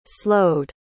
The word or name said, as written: slowed